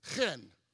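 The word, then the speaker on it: chen (חן): There's a chet, which is that CH, where we use it in the English language for character and chorus, choir, that CH, that hard C sound, and the nun, which is the N, so that CH and N, chen.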